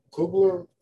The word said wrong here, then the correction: Kluber: How do you pronounce it? – I'll give it to you, Corey Kluber.